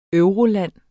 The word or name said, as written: euroland